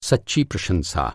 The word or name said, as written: प्रशंसा